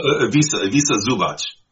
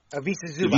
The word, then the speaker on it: Ivica Zubac: Is that how you pronounce it? – Well, Ivica Zubac. – Ivica Zubac. – Ivica Zubac. Yeah. Yes.